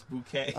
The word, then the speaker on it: bokeh: No, it's not bokeh. It's B-O-K-E-H. – It's bokeh? – Bokeh. It's not bokeh, it's bouquet. – Oh, it's like bokeh. – Yes. – Oh, it really is. It's properly pronounced with bo as in bone and k as in Kenneth.